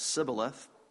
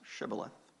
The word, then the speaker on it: Shibboleth: I know some of you on both sides of the political aisle who have been ostracized because your politics said Sibboleth when every intelligent, godly person knows it's pronounced Shibboleth.